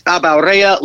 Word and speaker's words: Abaurrea: On Twitter, @ Nate Abaurrea, last name A-B-A-U-R-R-E-A,